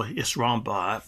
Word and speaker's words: Sramba: You pronounce the title Isramba, but it's spelled S-R-A-M-B-A if you want to check out more information about it.